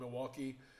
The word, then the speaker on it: Milwaukee: Milwaukee. And as Alice Cooper says in Wayne's World, says, it's actually pronounced Milwaukee, which is Algonquin for the good land.